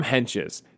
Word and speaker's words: Hentges: Here's the thing about his name. You see it, and you see that T in the middle of his name. You blow straight through that T. That's how you say his name, Sam Henches.